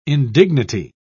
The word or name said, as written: indignity